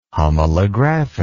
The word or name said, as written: homolographic